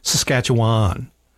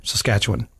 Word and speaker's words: Saskatchewan: Because a lot of people say Saskatchewan. They overpronounce that last syllable. – Why, what? How is it supposed to be said? – Saskatchewan. – Oh, is it? – Yeah. – I say Juan.